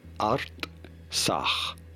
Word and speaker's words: Artsakh: How do you go about pronouncing it? Artsakh. Artsakh, no. – The Republic of Artsakh. Here are more videos on how to pronounce more countries. – Artsakh. – Artsakh. – Artsakh. – Pronouncing it. – Artsakh. – Artsakh. – Artsakh. – Bless you. Okay. – Jesus. – No, yep. It's Artsakh.